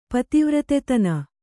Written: ಪತಿವ್ರತೆತನ

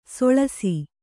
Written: ಸೊಳಸ

